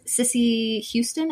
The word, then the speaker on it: Cissy Houston: Um, throughout high school, uh, he developed an affinity for legendary Motown artists, especially the all-female acts Aretha Franklin, S- Cissy Houston. Am I saying that right? – Mm-hmm.